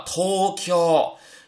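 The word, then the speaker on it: 東京: 比如说森林读作心林，那么以 ng 结尾、以 ou 结尾、以 ao 结尾的话呢，都读长音。比如东京读作 Tokyo，优变读作 u 兵，少年读作 shonen。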